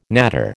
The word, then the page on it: natter: American English